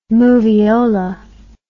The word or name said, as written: movieola